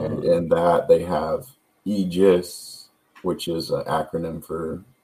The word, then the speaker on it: Aegis: Yeah, and it's pronounced Aegis, so the A is silent, and I was trying to use the logo in a way to make you see it. – Pronounce the E more. Forget about the A.